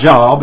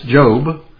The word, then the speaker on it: Job: And his name is not Job, it's Job. If you first looked at it in your index, it would say the book of Job to you, but it's a long O, it's the book of Job.